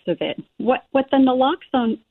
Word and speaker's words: naloxone: Now, what is um, is it pronounced noxalone? It's pronounced naloxone naloxone.